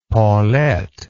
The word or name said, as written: Paulette